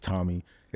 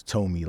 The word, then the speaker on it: Tomi: Tomi. So, yeah, it's obviously spelled T-O-M-I. People get it mixed up. They think it's Tomi. It's Tomi, like Tomi.